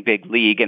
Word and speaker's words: big league: Some great linguists out at Stanford did a visual analysis of the audio file, and they recognized, because of the glottal stop or something, that he actually pronounced the G. So big league, and so he was actually saying big league, and that's the way he thinks of it.